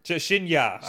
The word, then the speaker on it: Shinya: Shinya, I added, syllables there.